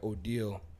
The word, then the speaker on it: Odeal: So, in terms of projects, I was into this guy, Odile, that's how you pronounce it. He put out an album. – Is it O-D-E-L-L? – O-D-E-A-L.